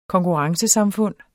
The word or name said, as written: konkurrencesamfund